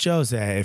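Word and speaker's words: José: It's Jose, if you're Portuguese, which he is. – Is it? – Yeah. – Oh, okay. I don't know. – They pronounce the J like that. The J.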